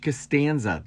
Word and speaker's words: Costanza: George Costanza.